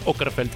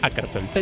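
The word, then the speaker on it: Åkerfeldt: Y aplaudo a Ann por usar la pelotita arriba de la A que lleva Okerfeld en sueco, que hace que se pronuncie Okerfeld y no Akerfeld.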